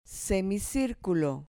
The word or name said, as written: semicirculo